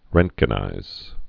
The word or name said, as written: roentgenize